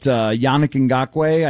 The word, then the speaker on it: Yannick Ngakoue: And I was looking at Yannick Ngakoue, I think is how it's pronounced, the guy from the Jaguars.